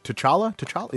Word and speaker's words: T'Challa: And then it turned out the king, King T'Challa, yeah, that's how you say it,